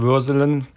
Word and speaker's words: Würselen: Sagt man eigentlich Würselen oder Würseln? – Also wir haben da eine ganz, ganz komische Aussprache und Dialekt. Also deswegen, ich selber sage Würselen.